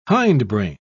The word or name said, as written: hindbrain